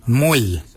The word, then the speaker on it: mooly: Warrigal is spelled W-O-R-R-A-G-O-L. Warrigal. Warrigal is your upper mouth. For your nose, we say mull. Mull is your nose. Mull is spelled M-O-O-L-Y.